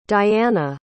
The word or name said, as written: Dyanna